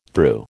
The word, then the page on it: thru: American English